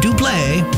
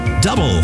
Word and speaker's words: Double: Not duble, proper pronunciation, double.